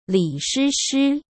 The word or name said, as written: Li Shishi